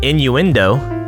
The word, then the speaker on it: innuendo: That's not what in the window means? – In the window, but innuendo.